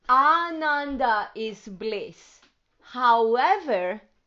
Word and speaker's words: Ananda: remember that the first A is long and it's Ananda. And you don't even have to pronounce it. When you think it in your head, think the long A.